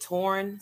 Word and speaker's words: Torrin: Um, I pronounce it Torn, so it's like Lauren with a T.